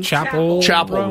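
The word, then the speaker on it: Chappell: Tyler wants to know my take on, well, how do you say her name? Chappelle Roan? – Chappelle Roan.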